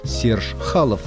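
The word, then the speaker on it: Chaloff: Прощание, я просто обязан дать слово, то есть место у микрофона, баритон-саксофонисту Сержу Чалову. Конечно, его фамилия пишется через два F, а русское произношение на российских сайтах Серж Халов. Его дед эмигрировал из России, и именно он носил фамилию Халов.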